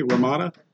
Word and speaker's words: Romita: At the height of his fame, he actually pitched a comic to Playboy that would be drawn by John, I always say this name wrong, Ramita, R-O-M-I-T-A, Ramita.